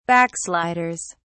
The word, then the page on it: backsliders: British English